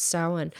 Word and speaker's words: Samhain: The origin of Halloween dates back over 2,000 years and evolved from the Celtic festival of Samhain, which kind of looks like Samhain, but it's pronounced Samhain.